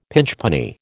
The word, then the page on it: pinchpenny: American English